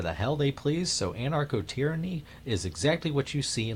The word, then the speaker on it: anarcho-tyranny: What I've referred to before, you know, I called it a narco-tyranny in this as a comparison, but the actual term is anarcho-tyranny. And I've butchered that before on the Alan Nathan show as being narco-tyranny or narco-state. It's actually anarcho-tyranny. Somewhere along the course of the years, I dropped the an.